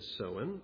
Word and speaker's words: Samhain: That's actually spelt in a way that we wouldn't expect, S-A-M-H-A-I-N, but it is apparently pronounced Samhain.